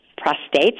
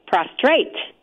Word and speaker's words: prostate: I do know a fair amount about prostates, and I'm going to learn even more. By the way, lots of people say prostrate rather than prostate. And so it's mispronounced by a lot of people.